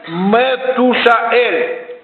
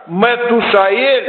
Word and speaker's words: Метушаэль: В действительности имя Каянита Мафусала произносится как Метушаэль. Вот Каянита Мафусала надо произносить как Метушаэль.